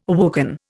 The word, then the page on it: awoken: Indian